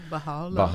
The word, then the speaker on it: Bahá'u'lláh: Okay, and there's this Bahá'u'lláh, if I'm pronouncing that correctly. – Yeah, Bahá'u'lláh.